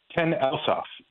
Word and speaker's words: Ten Elshof: I want to hear you say it. – Ten Elshoff. – Ten Elshoff. Okay, then I did say it right.